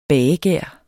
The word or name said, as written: bagegær